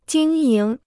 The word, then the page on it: 经营: jīng yíng